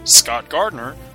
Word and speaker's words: Scott Gardner: You can find me, Scott Gardner, both on Twitter and Facebook. My name is spelled S-C-O-T-T-G-A-R-D-N-E-R.